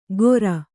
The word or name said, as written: ಗೊರ